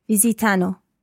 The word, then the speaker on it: visitano: Visitiamo, visitate, visitano. Okay, well done. But just watch your accent on the third person plural. Visitano.